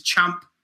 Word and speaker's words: Ciampa: Just to clarify for all you ignorant podcasters, journalists and fans out there trying to hop on the bandwagon, proper pronunciation, Ciampa is Ciampa.